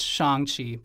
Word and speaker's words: Shang-Chi: By the way, I know it's pronounced Shang-Chi, but anyways, it fit for the Shang-Chi Wang-Chi joke.